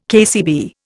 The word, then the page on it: KCB: Indian